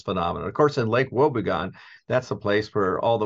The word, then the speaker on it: Wobegon: So obviously you are the founder of the, essentially the Lake Wobegon. Wobegon? How do you pronounce it? – Wobegon. – Wobegon. Wow. I was off.